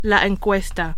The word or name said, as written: encuesta